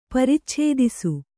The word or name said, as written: ಪ್ರಚ್ಛಾದಿಸು